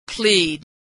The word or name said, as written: plead